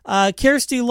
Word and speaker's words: Kjersti: Kjersti Long, Care Like Care Bear is how I was told to pronounce it.